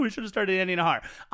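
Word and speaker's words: Najar: And if you say Andy Nahar, before you type in the J that gets pronounced like an H in his name, just turn off whatever device you're using and go to bed.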